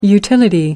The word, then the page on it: utility: American English